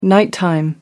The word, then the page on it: nighttime: American English